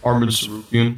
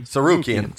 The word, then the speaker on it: Arman Tsarukyan: Sarkurian. How do you say it? Arman Tsarukyan. Tsarukyan. Tsarukyan.